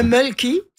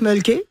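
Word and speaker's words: Mölkky: Alors, ça s'appelle le mulky. On prononce mulky. – Ouais. – Et c'est M-O-L-K-K-Y.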